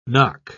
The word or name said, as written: nock